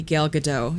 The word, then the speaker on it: Gal Gadot: I could spend the whole movie just watching Gal Gadot be Gal Gadot.